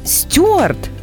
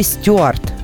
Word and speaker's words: стюард: Вот человек, который работает на борту самолета, он стюард или стюард? Ну, до недавнего времени там работали стюарды. Угу. А, потому что в словарях только такой вариант был еще совсем недавно. То есть с ударением на «ю». На «ю», да.